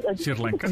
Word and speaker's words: Sri Lanka: me cuesta mucho trabajo pronunciar, pero Sri Lanka. ¿Cómo? – Sri Lanka. Sri Lanka. O Sri, Lanka. Como que la R me cuesta trabajo.